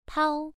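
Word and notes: pao: tone 1

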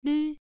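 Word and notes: lv: tone 1

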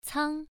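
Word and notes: cang: tone 1

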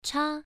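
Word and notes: cha: tone 1

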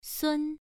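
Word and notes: sun: tone 1

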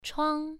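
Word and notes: chuang: tone 1

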